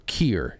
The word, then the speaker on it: kier: They tweeted out this of a definition of a word called kier. It's K-I-E-R. It's pronounced kier.